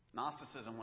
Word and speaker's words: Gnosticism: Gnosticism with a silent G at the beginning. G-N-O-S-T-I-C-I-S-M.